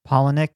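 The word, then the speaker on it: Palahniuk: I think it's Polonick. – Polonick, yeah, Chuck Polonick, the writer of Fight Club, the novel.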